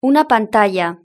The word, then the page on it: una pantalla: European Spanish